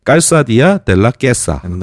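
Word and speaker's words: Calzadilla de la Cueza: 이 까리온부터 그 다음 마을 깔사디아 델라 꾀사 뭐라고 해야 되지? – 그 현지 발음으로는 깨싸가 맞을 거예요. – 깨싸가 맞겠죠? – 네, 그러니까는 Q-U를 네. 굳이 Q로 안 읽어도 돼요.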